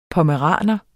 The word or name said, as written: pommeraner